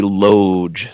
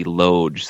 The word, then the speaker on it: loge: It's called the loge section. The loge section.